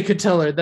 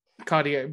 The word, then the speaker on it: Cotillard: No, it's French. – I know. – You can't say Cotillard. That's given too much credit to the letters that they use.